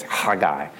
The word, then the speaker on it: Haggai: So that's Haggai's name is actually also a spitting name. It's Haggai. So if you really want to be ultra correct on his pronunciation of his name, you have to step back from somebody and then pronounce it.